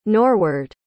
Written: norward

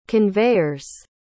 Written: conveyers

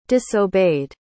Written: disobeyed

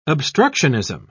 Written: obstructionism

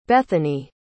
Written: Bethany

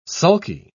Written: sulky

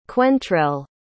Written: quentrell

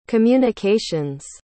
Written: communications

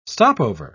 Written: stopover

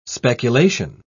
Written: speculation

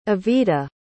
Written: Evita

